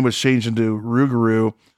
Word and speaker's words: rougarou: Rougarou.